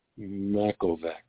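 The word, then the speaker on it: Machovec: Makovec.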